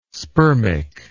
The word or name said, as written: spermic